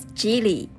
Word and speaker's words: Jili: Uh, my name is Jili. That's pronounced like uh, Chinese Jingyi.